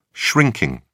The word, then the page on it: shrinking: British English